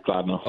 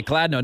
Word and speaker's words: Kladno: Now, your hometown is it Kladno? Is that how I pronounce it? – Yeah, Kladno. – Okay, Kladno.